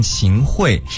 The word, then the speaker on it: 秦桧: 你知道吗，刚刚我查了一下，就是关于秦桧还是秦桧的这个读音，最后发现是应该念秦桧。